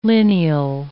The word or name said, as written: lineal